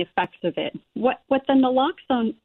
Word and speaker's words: naloxone: Now, what is it pronounced naloxone? – It's pronounced naloxone. – Naloxone.